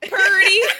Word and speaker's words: Purdy: How do you say her name? Pure D? – Yes. – All right. I was just like, is it Pure D? – Purdy. – Just like that.